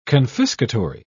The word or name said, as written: confiscatory